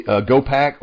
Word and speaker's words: hopak: It's called a hopak. I think I'm saying it right. H-O-P-A-K. It's a hopak. It's also called the gopak or the Cossack dance, I think.